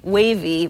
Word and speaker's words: Wavii: That's exactly how an app called Wavy, which is spelled W-A-V-I-I, Wavy, works. It's not unlike an app like Circa or Sumly. We've talked about both of those apps on previous episodes. Those attempt to summarize news, except Wavy is more specifically a collection of topics.